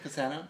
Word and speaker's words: Cassano: Instagram, Mateo Cassano Icons. – How do you spell it? – M-A-T-T-E-O. – Uh-huh. – C-A-S-S-A-N-O. – Uh-huh. Is that your real name, Cassano? – Mateo Cassano, yeah.